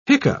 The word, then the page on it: hiccough: British English